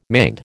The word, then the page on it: manned: American English